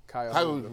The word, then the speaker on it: Cuyahoga: And in case you're not sure how that's pronounced, it's Cuyahoga Community College. – Cuyahoga.